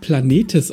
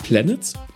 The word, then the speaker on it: Planetes: Und PPS, das wird tatsächlich Planetes ausgesprochen, weil es das griechische Wort für Wanderer ist, von dem sich auch das Wort Planet ableitet. Also eigentlich heißt der Manga nicht Planets, habe ich auch angenommen, dass er so heißt, sondern Planetes.